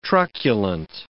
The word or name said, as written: truculent